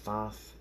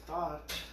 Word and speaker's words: Thoth: It's Thoth, spelled with a T-H-O-T-H. Some people call it Thoth, some people call it Thought, some people call it Thoth. It's whatever is appropriate to your pronunciation.